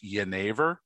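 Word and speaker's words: jenever: Uh, it starts with a letter J. I think it's correctly pronounced Yenever.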